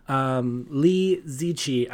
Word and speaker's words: Liziqi: Lee Zichi. I had to go look how it's pronounced. It's spelled L-I-Z-I-Q-I.